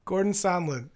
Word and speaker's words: Gordon Sondland: Gordon Sondland.